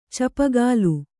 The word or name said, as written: ಚೂಪುಗೋಲು